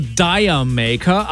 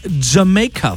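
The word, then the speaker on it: D'yer Mak'er: Man könnte meinen, der Songtitel, der wird irgendwie ausgesprochen so Dire Maker. Aber eigentlich ist es nur ein Wortspiel, so ein englisches. Und der Songtitel ist Jamaica von Led Zeppelin.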